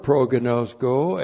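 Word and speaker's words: pro-gnosko (προγινώσκω): Looks like that in the Greek, that's P-R-O-G-I-N, long O, S-K, long O, pro-gnosko.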